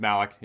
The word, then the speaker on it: Malek: So just Malek, yeah. – Malek, because he actually played...